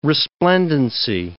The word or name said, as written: resplendency